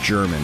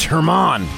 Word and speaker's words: Herman: And kept calling him German. And finally said, somebody said, Wiley, it's Herman. Would you pronounce it right and quit calling him German?